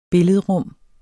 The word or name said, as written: billedrum